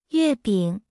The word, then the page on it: 月饼: 0.8x